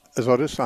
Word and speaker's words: Azotus: Azotus, if I'm saying that right. – Azotus, uh-huh.